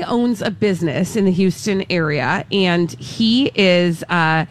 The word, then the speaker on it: Houston: Let's go to Houston. Houston, Texas. – It's Houston. – Sorry, Houston. So no, so we don't pronounce the H on that? – Not in Texas. You wouldn't say Houston.